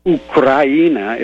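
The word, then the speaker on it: Ukraina (Украина): Ukraina is how it's pronounced in Russian.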